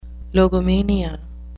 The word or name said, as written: logomania